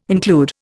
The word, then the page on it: include: Indian